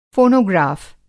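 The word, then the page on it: फ़ोनोग्राफ़: fonogrāf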